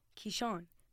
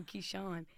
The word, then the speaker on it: Keyshawn: Keyshawn. Croutons? And I'm like, no, Keyshawn.